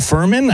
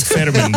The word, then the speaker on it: Fermin: If I said it right, the sound Furman? I don't think I like San Furman. – I like Fermin better. I'm going to say that with confidence, okay? F-E-R-M-I-M.